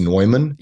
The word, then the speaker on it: Neumann: So it's called a Newman, spelled N-E-U-M-A-N, but I think pronounced Neumann.